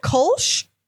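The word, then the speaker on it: Kölsch: But like, we would stand there and be like, oh yeah, the Kolsch. And they're like, oh, that's how you say it.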